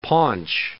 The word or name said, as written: paunch